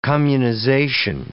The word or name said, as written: communization